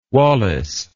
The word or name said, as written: walays